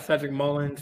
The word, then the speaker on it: Cedric Mullins: They got Cedric Mullins still. I mean, they got. Did I get his name right? – Yes, you got that one.